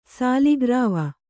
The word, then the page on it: ಸಾಲಿಗ್ರಾವ: sāli grāva